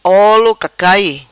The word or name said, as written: Olo cacai